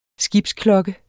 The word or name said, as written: skibsklokke